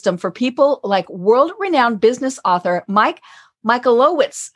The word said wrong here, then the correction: Michalowicz: I probably said that wrong. Mike Michalowicz. – That's it, Mike Michalowicz.